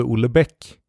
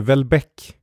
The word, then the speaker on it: Houellebecq: Så att personer med hög kulturstatus, de säger ju inte Houellebecq. De säger Houellebecq.